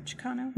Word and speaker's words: Chicano: Did I say that right? Chicano? – Mm-hmm. You did, but the question is...